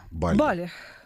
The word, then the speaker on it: Бали: Категорически против Таиланда, гораздо лучше отдыхать на Бали или Бали, говорить правильно? – Бали.